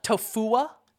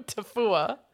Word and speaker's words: Tofua: Tofua.